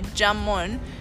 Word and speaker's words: jamón: Yes, because the J sound in Spanish is an H sound, so jamón, which is a kind of smoked pig.